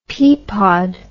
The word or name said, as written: peapod